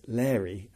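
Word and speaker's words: lairy: Well, I would pick you up on the pronunciation. First of all, it's Larry, as in rhyming with hairy.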